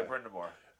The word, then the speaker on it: Brind'Amour: Yeah, Brind'Amour.